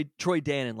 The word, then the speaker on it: Dannen: In the same way that Troy Dannon, like the yogurt, right? That Troy Dannon. – It's not spelled like the yogurt, but it is pronounced like the yogurt.